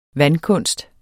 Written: vandkunst

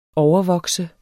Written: overvokse

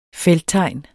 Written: felttegn

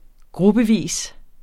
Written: gruppevis

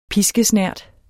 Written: piskesnert

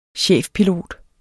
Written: chefpilot

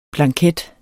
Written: blanket

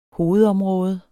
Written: hovedområde

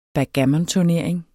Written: BACKGAMMONTURNERING

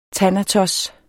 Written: thanatos

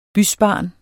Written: bysbarn

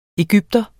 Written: EGYPTERE

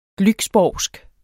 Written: glücksborgsk

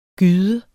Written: gyde

